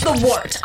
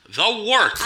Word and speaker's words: Wort: This is The Wort on Govs Radio. – It is The Wort, not warts, not forts, not short, The Works.